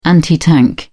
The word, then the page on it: anti tank: British English